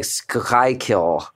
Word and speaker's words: Schuylkill: Schuylkill. That's a river, right? – Schuylkill.